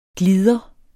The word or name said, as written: glider